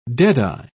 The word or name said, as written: deadeye